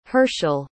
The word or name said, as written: Herschel